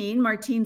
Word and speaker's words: Martine: And then, of course, my first name, Martine speaking French. And so the only time my name is pronounced when I went to Starbucks was in France.